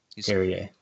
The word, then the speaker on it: Carrier: Is it Carrier or Carrier? – I think it's Carrier because he's from the Q. – Carrier. There you go. Yeah. Oh, yeah. He was born in Quebec. Carrier. Said it right the first time.